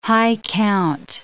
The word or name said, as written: high-count